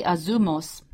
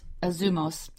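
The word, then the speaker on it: azumos (ἄζυμος): It's azimos, azimos, it's really azumos, it's pronounced azumos.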